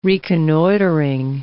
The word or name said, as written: reconnoitring